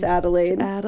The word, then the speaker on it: Adelaide: I'm pretty sure it's pronounced Adelaide. – It absolutely is. – A-D-E-L-A-I-D.